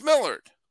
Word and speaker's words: Millard: So when I see G-A-L-L-E-N, like here's my friend Millard. How do you spell it? M-I-L-L-A-R-D. That's Millard. – It's Millard.